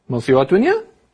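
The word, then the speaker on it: Mosi-oa-Tunya: Charlotte, do you know how to pronounce this? Because you wrote Mosiwatunya? Mosiwatunya? – That sounds pretty good.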